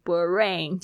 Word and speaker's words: brain: 这个 br 呢，它也要一起念，别你不要念成 b 了 b 了哦，变成 brain 就不对。